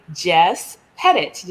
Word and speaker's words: Jess Pettitt: with Jess Pettit. Did I say that correctly? – Sure did.